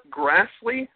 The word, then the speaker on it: Grassley: Senator Chuck Grassley, is that how you pronounce it? – Yes.